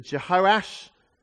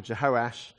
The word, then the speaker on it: Jehoash: We read in the beginning of chapter 12 that Jehoash, who's also called Joash, confusingly, you can say his name the short way or the long way, so it's like Andrew or Andy, or his Joash or Jehoash.